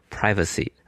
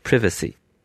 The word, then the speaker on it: privacy: 隐私这个词呢，如果是美式英语，一般读作 privacy 啊，英式呢会读作 privacy。